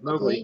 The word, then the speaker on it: Mowgli: Is it Mowgli? Is that right? Yeah, Mowgli.